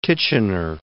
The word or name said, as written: Titchener